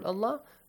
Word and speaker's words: الله: The word Allah is pronounced with an a sound. – Yes. – يستغفر الله يجد الله. Alright, so we do not pronounce it with an R sound.